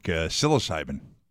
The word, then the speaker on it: psilocybin: I think it really could have freaked you out, and it would have been like psilocybin. – You know, well, it's got a silent P.